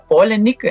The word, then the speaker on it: Palahniuk: Me dice el internet que se pronuncia Palahniuk, entonces más bien es de Chuck Palahniuk,